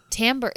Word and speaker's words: tambour: They were just like, for anyone who's never, because I had never heard of tambour. – Am I saying it right? Is it tambour or tambour? – Tambour. It's like Jeffrey Tambour. like the actor.